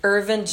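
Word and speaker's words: Earvin: Is it pronounced Irvin? Even though there's an EA? Oh, okay. So Irvin Johnson.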